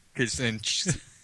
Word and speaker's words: Kaczynski: Never go Kaczynski.